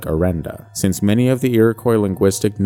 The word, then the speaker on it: Iroquois: Iroquois. – Yes, Iroquois.